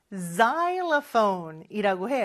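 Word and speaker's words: xylophone: 마지막으로 실로폰은 여러분 xylophone이라고 해요. X로 시작하죠. xylophone인 거, 요거 발음 잘 기억해 주셔야 돼요.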